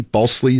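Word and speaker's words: Balsillie: Balsley is how you say his name.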